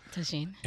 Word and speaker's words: tagine: I'm looking for recipes that are less sweet and more savory, and can we cook Indian food in the tagine? Is that how you say it? – Yeah, tagine.